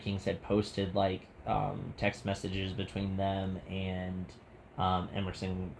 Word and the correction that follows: Everson: Griffin, or yeah, if I said that right, or Everson. Like Ever. – Yeah, Everson Griffin.